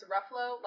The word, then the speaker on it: Ruffalo: I literally sat her down and I was like, Mom, it's Ruffalo, like Buffalo.